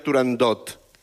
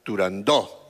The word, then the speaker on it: Turandot: No se dice, no se debe pronunciar Turandot. – Ah, no, Turandó. – La ópera se titula Turandó.